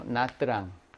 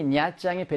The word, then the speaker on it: 냐짱: 근데 남부에서의 발음은 이걸 냐짱 이렇게 해. 짱이라고. 트랑이라고 들린단 말이야. 뜨랑이라고 분리해서. 근데 우리는 지금 북부 뭐 베트남 수도 하노이를 중심으로 하니까 거기서는 이것을 그냥 냐짱 이렇게 발음을 하거든요.